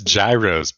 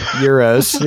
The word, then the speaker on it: gyros: That's not even how you pronounce it. You're offensive. – Yeah, it's gyros, bro. – It's not that either. – As somebody that's eaten many gyros, I know how to pronounce it. – Euros? – Euros, yeah. – That is an offensive pronunciation of that word, so please don't do that anymore.